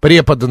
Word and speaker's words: препода: Работаю в школе 11 лет, еще пока категория молодого препода. Преподаю физику. – Препода, наверное, все-таки препода. – Я не знаю, почему я прочитала препода. – Препода, препода. Это ударение современных детей.